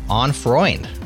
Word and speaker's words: On Freund: On Freund.